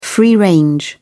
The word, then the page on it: free-range: British English